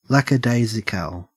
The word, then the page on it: lackadaisical: Australian English